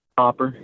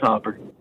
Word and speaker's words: Hopper: How do you say your last name, Hopper or Hooper? – Hopper. It's Hopper.